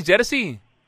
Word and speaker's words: Jerzy: Lo nuestro, sinceramente, con el Padre Franco, más de decirte Jerzy, en polaco no vamos a decirte. – También, sí. – ¿Se dice así, Jerzy? – Sí. Jerzy, ese puede ser, sí.